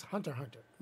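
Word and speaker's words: Hunter × Hunter: Or some, is now it's pronounced Hunter Hunter. It's on Netflix. And some people were like, oh, watch Hunter X Hunter. No, it's Hunter Hunter.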